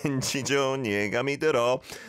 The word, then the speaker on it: 왠지 좋은 예감이 들어: Perfect pronunciation as well. Remember 왠지 for some reason, but you can't put your finger on it.